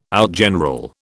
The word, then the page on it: outgeneral: British English